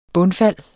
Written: bundfald

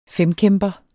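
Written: FEMKÆMPER

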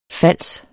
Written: fals

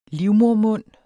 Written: livmodermund

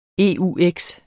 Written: eux

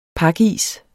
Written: pakis

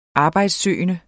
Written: arbejdssøgende

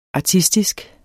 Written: ARTISTISKE

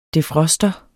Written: defroster

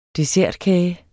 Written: dessertkage

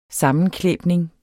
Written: sammenklæbning

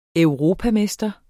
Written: europamester

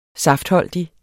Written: saftholdig

